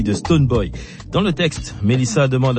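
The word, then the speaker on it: Stonebwoy: On va écouter la révélation Melissa et le patron de l'Afro-Dance Hall ghanéen Stone Boy sur le titre Show Me. – Absolument Lisa, et bravo pour la prononciation de Stone Boy.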